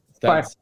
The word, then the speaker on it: Sparklet: Sparklet.